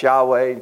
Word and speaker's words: Yahweh: And the best guess that we have, he says, is pronounced Yahweh.